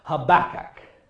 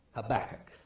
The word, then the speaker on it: Habakkuk: Now, sometimes you have to sound like you're coughing when you say that. Habakkuk. Habakkuk.